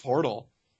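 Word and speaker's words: Pöltl: I think it's just Portal. – Portal. Yeah. – It just spelled weird.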